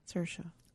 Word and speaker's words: Saoirse: Just like Saoirse is you know, that's how you spell it. – Saoirse. – Whatever. – Like inertia.